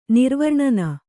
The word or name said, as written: ನಿರ್ವರ್ಣನ